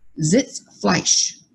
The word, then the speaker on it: Sitzfleisch: Sitzfleisch, or chair glue.